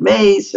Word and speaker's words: Myss: And her name looks like it's Carolyn, but it looks like M-Y-S-S, but she pronounces it Mace.